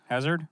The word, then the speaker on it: Hazard: Hazard? – Yeah.